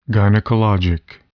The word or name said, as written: gynecologic